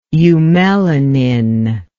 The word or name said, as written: eumelanin